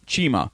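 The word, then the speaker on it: Txema: Now, it's spelled TX. EMA, but it's pronounced Chima.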